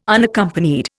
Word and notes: Unaccompanied: Indian

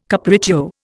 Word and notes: capriccio: Indian

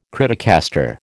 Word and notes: criticaster: American English

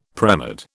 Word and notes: premed: British English

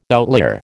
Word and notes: outlier: American English

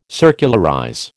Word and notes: circularize: American English

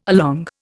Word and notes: along: Indian

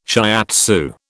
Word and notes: shiatsu: British English

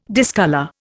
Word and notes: discolour: Indian

